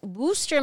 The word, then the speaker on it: Worcester: And I'm going to pronounce it right. It's Worcester, Massachusetts, but it's not spelled that way.